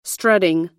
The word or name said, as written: strutting